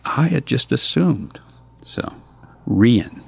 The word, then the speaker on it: Rian: I wanted to delve into the character, and just for the sake of argument, her name is pronounced Rian, and I chose that name. It's Irish, and it is used as both a boy's and a girl's name, and that's going to become very germane as the narrative progresses.